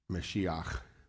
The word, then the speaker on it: Mashiach: That word, Messiah, which we're mispronouncing, Mashiach, is the way you'd say it in Hebrew.